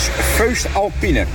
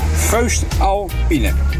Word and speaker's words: Voest Alpine: Ik zeg, hoe spreek je dat uit? Dat Voest Alpine. Nou, let op, daar komt het. – Dat is Voest Alpine. Voest Alpine.